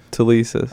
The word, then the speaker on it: Telesis: Telesis.